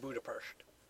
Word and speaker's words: Budapest: I believe it's pronounced Budapest.